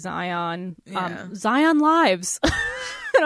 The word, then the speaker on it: Xion: But it's funny, too, because there's a character in the game called Xion, but it's spelled X-I-O-N. So, like, I would never pick up how to Zion. – Pronounce that.